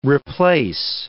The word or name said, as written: replace